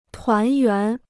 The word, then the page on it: 团圆: tuán yuán